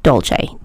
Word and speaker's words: Dolce: You can just look up my name, Stephanie Dolce. D-O-L-C-E.